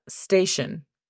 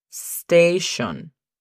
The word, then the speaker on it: station: La pronunciación fonética es S-T-E-I-S-H-O-N. Station. – Station. – Cuidado con la E, que siempre se mete. Station.